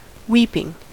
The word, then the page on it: weeping: American English